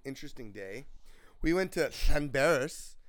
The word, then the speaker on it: Llanberis: That's because Llanberis is how you pronounce that name, because it's a double L-L. It's L-L-A-N-B-E-R-I-S, I think.